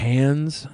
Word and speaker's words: Cannes: Cannes.